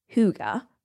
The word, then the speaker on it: hygge: It's also spelled in an interesting way if you want to look it up, H-Y-G-G-E, but it's pronounced Huga.